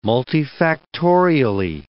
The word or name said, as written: multifactorially